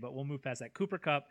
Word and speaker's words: Cooper Kupp: And that is Cooper Kupp out of Eastern Washington, whose name I probably also mispronounced because that's... – No, you got it right. – I got that one right.